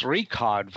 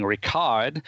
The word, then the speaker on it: Richard: So there were professional aspects to the production, but that didn't extend to the pronunciation, the correct pronunciation of the characters' names, because it's Richard Wagner, but people kept saying Richard, and it's Cosima Wagner, but people kept saying Cosima as if she were Italian.